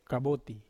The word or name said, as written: kaboti